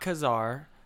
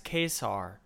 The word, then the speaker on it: Ka-Zar: So not Ka-Zar, it's Ka-Zar. Wow. Like K-A-Y-S-A-R.